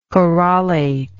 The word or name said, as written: corollate